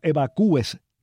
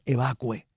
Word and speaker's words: evacue: que yo evacúe, que tú evacúes. No es evacúe, es evacue. Ese acento es un disparate. Así que recuérdenlo ustedes. No se dice yo evacúo, sino yo evacuo.